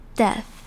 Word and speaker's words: death: 首先我们来看 death 这个字，death 这边的 ea 读成 e 的声音，而后面的 th 是无声的 th，是 s 这样子有气音的感觉，death，death。 Death, 接着我们来看 rose 这个字，rose 这边的 o 读成长母音 o 的声音，而这边的 s 是读成比较像字母 z 这种 z 的发音，所以是 rose。